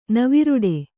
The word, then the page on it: ನವಿರುಡೆ: kannada